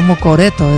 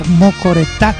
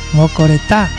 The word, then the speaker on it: Mocoretá: No, es que yo antes decía Mocoreto, decía. Bueno, claro, es que no me pronunciaba bien Rocío, no me decía bien el nombre, pues. Entonces yo no le escuchaba bien como decía. Hasta que escuché bien a Carlito que decía Mocoretá. ¿Ah? Mocoretá.